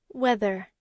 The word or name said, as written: whether